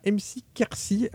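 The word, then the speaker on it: MC Ker6: il y a MC Kersi. Alors au début, quand j'ai vu, j'ai compris le blaze après avoir écouté la musique, parce que ça s'écrit MC Ker, donc K-E-R et 6,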